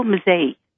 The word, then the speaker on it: Mazzei: Filippo Mazzei. – Mazzei. – M-A-Z-Z-E-I.